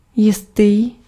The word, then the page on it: sûr: [syʁ]; France